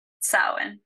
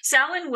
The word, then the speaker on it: Samhain: Yes, Samhain is not the word. – Right. – Samhain. It's a Gaelic word, and it's a tricky language.